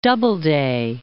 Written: Doubleday

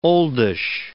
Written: oldish

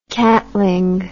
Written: catling

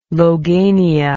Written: logania

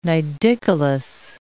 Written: nidicolous

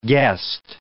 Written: guest

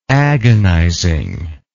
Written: agonizing

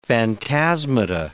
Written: phantasmata

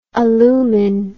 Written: alumin

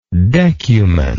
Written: decuman

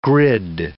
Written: grid

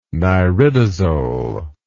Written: niridazole

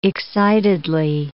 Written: excitedly

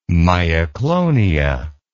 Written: myoclonia

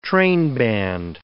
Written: trainband

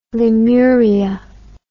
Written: Lemuria

